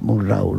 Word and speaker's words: Murnau: iban a ver cómo rodaba Murnau. O sea, el desembarco de Murnau es apabullante y su influencia hasta ahora mismo. – Como dirías tú, Wilhelm Friedrich Murnau, ¿no?